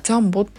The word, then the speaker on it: 両棒: なんで両方の棒で当時からジャンボって読んだんですかね。このあ書いてありましたこの両方の量に棒をジャンボと読むのは中国から来ている